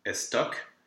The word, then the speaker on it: estoc: if you just see a vowel with a C, yes, it will be pronounced. Here we have estoc, which is an old fencing term. – Mm-hmm. – So not very common. You will never use that ever again. and bec. That you will. That's just a word for beak, so that's actually a common word. And both C's are pronounced.